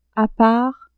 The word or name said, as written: a part